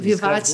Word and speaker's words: vivace: Vivace significa um vivace. movimento em música, porque é italiano, mas se pronuncia vivace.